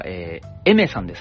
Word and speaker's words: Aimer: さあ、そして初出演が、エメさんですね。ついに来たかって感じですよね。アルファベットで AIMER と書いてエメさんでございます。